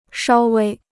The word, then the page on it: 稍微: shāo wēi